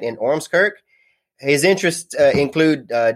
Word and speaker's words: Ormskirk: Today, we are talking to Anthony Grant um, of Edge Hill University in, did I say this right, Ormskirk? Yes. Ormskirk in the in in the UK.